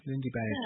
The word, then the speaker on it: Lindy Beige: L-I-N-D-Y? – And beige, like the color beige. – Beige, oh, okay. Lindy Beige.